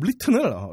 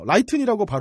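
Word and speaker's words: written: 그리고 제 발음 리튼을 라이튼이라고 발음한 걸 지적해 주셨는데, 이게 보통 영어를 이렇게 소리내서 읽을 일이 크게 없어서 저는.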